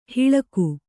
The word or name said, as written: ಹಿಳಗು